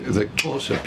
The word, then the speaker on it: Xhosa: The Xhosa people, for example, it's spelt X-H-O-S-A, but you have to have this sound out of this when your tongue draws back from the roof of your mouth.